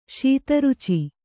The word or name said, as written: ಶೀತರುಚಿ